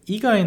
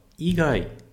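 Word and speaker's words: igai (以外): Então, sugaku igai nara benkyou tetsudaemasu yo, e presta atenção nessa palavra, igai. Igai, agora é uma pronúncia de cima para baixo.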